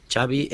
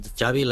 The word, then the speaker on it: Xavi: Xavi Hernández. – Xavi, okay. – Yeah. So I heard the... Xavi like chocolate. – Yes. Okay, thank you very much. – Xavi like chocolate, dice el tío ahí, bien tirado, Xavi.